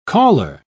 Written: caller

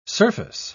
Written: surface

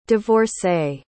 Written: divorcée

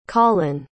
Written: Collen

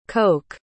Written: koch

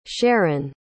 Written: sharyn

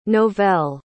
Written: novell

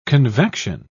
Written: convection

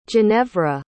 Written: ginevra

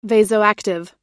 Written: vasoactive

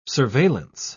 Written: surveillance

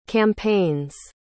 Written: campaigns

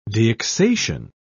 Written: deaccession

